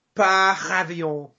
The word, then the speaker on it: Par avion: Par avion. – Oh, there it is. Nice and Frenchy. – How do we say it? Help me out. Like, Per avion in English. Per avion, please. American English.